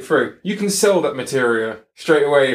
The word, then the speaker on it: Materia: Whenever you get to the breeding farm for that, you get, and here's the next one I'm going to mispronounce, Metra or Materia? Or it's Materia.